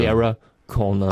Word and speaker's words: Sarah Connor: Also nicht Deutsch aussprechen, sondern Englisch. – Ja, also Sarah Connor. – Also man muss sich's vorstellen, wie wenn es mit einem E geschrieben wäre, Sarah, obwohl Sarah geschrieben ist.